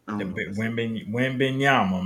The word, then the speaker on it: Wembanyama: Victor Wembanyama. Did I say it right? – Wembanyama, man, that's him.